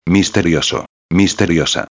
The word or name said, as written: misterioso-a